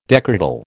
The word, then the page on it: decretal: American English